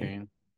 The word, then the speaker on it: Duquesne: Duquesne. – But also, I did not realize that's how you pronounce that.